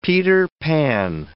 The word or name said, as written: Peter Pan